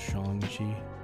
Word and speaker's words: Shang-Chi: I went to see Shang-Chi in the theater, first time in the theater for a while. – I think it's pronounced Shang-Chi.